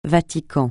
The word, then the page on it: Vatican: francais